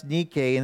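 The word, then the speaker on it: Nike (νίκη): This word, pronounced Nike in the original Greek language, forms the root word that is repeated several times in these two chapters that we find ourselves in today.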